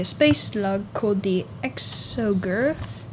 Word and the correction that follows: Exogorth: It's Gorth? Like Gorth, I guess? – Exogorth.